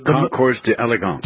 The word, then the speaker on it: Concours d'Elegance: The Concours d'Elegance.